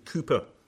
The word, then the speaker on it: Cowper: To judge by the contents, his favourites were hymns written by John Newton and William Cowper, or to those who don't know how to pronounce the word Cowper, but it's William Cowper.